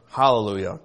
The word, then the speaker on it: Hallelujah: Now, how would you say that modern day? You put an H on the beginning of it. Hallelujah, correct? Same word.